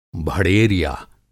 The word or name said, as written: भड़ेरिया